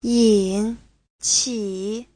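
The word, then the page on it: yinqi: yin3qi3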